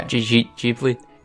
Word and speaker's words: Ghibli: For people who don't know, Ni No Kuni is a Japanese RPG that, oh man, Studio Ghibli? Is that how you pronounce it? Ghibli? – No, I think it's Ghibli. – Ghibli? Okay. – Ghibli.